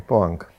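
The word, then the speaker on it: Poäng: Poeng, ako sa to vyslovuje? Ja neviem. Výborne, poang.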